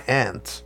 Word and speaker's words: aunt: Met my aunt. Aunt, is that how you say it? – It's good both ways. – They're both correct.